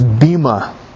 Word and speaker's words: bema: Now, notice in verse 10, and this is worthy of underlining, where it says judgment seat, that word judgment in the original language is pronounced bima, meaning reward.